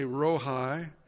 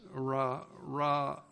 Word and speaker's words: Rohi: Jehovah, I'm going to say Rohi, R-O-H-I. The name Jehovah Rohi, it might be pronounced Ra-A.